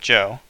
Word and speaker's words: Zhou: Around 1045 BCE, a frontier state called Zhou, pronounced Zhou, rose up against the dynasty.